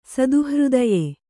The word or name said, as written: ಸದುಹೃದಯೆ